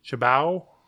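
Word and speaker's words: Cibao: He bought a Dominican Winter League team, the Gigantes del Chibao, or damn it, I always forget how to, it's C-I-B-A-O, Chibao. I forget how to pronounce that.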